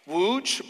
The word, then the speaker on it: Łódź: It's in Poland, and it's pronounced Łódź, but it is actually spelt L-O-D-Z. The L has that little line through the middle of it.